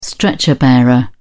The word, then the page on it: stretcher bearer: British English